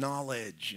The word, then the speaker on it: knowledge: The second manner in which the apostles serve God and provide an example for us is in gnose, in knowledge, in understanding. This is perhaps why knowledge in English starts with a K, even though we don't pronounce it.